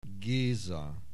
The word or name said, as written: Geza